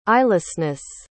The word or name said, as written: eyelessness